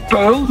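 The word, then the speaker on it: Puyl: Het is gewoon Puil, maar het wordt dus U-Y, dus uw lange ei wordt het geschreven.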